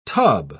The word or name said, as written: tub